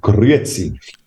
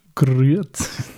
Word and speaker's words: Grüezi: Das heißt Grüezi. – Grüezi. – Das R musst du eben vorne rollen, vorne unter den Zähnen.